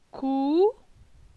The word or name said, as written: Cou